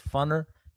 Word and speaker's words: Funner: Yeah, I said it right, folks. It's Funner, California.